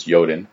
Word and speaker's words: Jotun: Jotun, I believe it's Jotun. I think the J is pronounced like a Y.